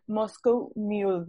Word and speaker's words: Moscow Mule: Eh, y su bebida favorita es el Moscow Mule.